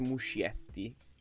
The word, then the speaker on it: Muschietti: Si legge Muschietti, anche se Muschietti.